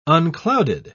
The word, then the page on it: unclouded: British English